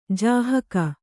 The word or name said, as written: ಜಾಹಕ